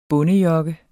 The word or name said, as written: bondejokke